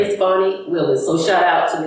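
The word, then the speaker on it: Fani: No, but on name pronunciation, I do need to apologize to Fanny. It's Fonnie Willis, and if you listen to one of our previous podcasts, I did her an injustice.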